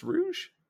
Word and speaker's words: Rouge: Isn't that Rouge the Bat? – Is it pronounced Rouge? – I'm pretty sure it's supposed to be French. Like Rouge.